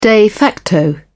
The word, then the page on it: de facto: British English